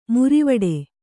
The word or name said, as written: ಮುರಿವಡೆ